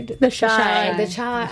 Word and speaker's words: The Chi: Chi? Is that how they... – The Chi.